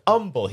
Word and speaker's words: humble: He says humble. He doesn't pronounce the H in humble. – It's an implied H.